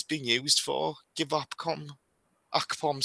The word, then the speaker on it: Akpom: Akpom. – Akpom.